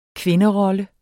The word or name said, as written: kvinderolle